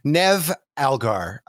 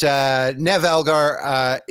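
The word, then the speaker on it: Niamh Algar: Her name is, I should have written it down, Nev Algar. And it's not pronounced the way that it's spelled because I think it's Gaelic, but Nev Algar,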